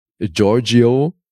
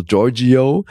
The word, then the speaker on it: Giorgio: Das ist nicht so schlimm, dass sie dieses Io am Ende immer so deutlich aussprechen, die Amis. Mhm. Aber ja, also Giorgio, der hat da so ein italienisches Restaurant, obwohl er eigentlich Puerto Ricaner ist.